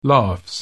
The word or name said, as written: laughs